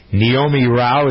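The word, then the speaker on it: Neomi Rao: Senate Judiciary Committee advancing the nomination of Naomi Rao. Is that how you pronounce her name? N-E-O-M-I? – Close, I think.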